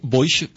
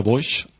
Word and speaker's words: Boix: i que pronuncia d'aquesta manera Boix, com fan els castellans, que no els hi surt la pronunciació de Boix.